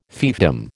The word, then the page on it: fiefdom: American English